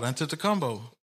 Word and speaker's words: Antetokounmpo: Akatumpo, what he say his name? – Antetokounmpo.